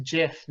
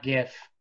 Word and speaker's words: GIF: It's pronounced JIF, not GIF. Just like the peanut butter. The Oxford English Dictionary accepts both pronunciations.